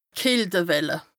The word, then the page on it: d' Kältewelle: Bas-Rhin; Strasbourg